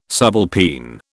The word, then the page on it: subalpine: British English